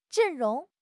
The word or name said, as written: 阵容